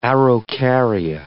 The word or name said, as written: araucaria